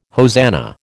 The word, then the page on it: hosanna: American English